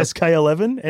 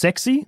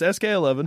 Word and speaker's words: SKXI: SK11, SKXI. People think it's pronounced sexy. – Yep, or ski. – Or I've, ski, I've or skexy. – Was, yeah, skexy. – No, it's SK11.